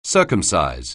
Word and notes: circumcise: anglais

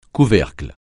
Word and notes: couvercle: French

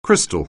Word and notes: crystal: anglais

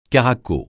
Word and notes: caraco: francais

